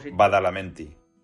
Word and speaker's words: Badalamenti: Angel Angelo Baladamenti, no sé muy bien cómo se dice. – Angelo Badalamenti, creo.